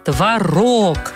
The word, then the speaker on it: творог: Правильно говорить, конечно, творог. Ударение у этого вкусного и полезного продукта всегда на второй слог.